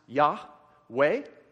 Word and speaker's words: Yahweh: Okay, so when you hear people say Yahweh or Jehovah, that's just two different ways of pronouncing the same name, which is this right here. And Yahweh is probably closer to the right thing.